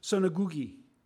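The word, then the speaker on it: sunagogē: The word Greek here in some translation is spelled S-U-N-A-G-O-G, synagogi.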